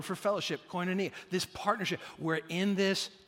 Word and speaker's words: koinonia: This Bible word for fellowship is the Greek word koinonia. That's how it's pronounced in Greek, koinonia.